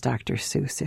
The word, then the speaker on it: Dr. Seuss: And then tell us how Theodore Geisel came to be known as Dr. Seuss, if you would, please. – Well, you are pronouncing it correctly, although a lot of people pronounce it Dr. Seuss many times, but you've got it right.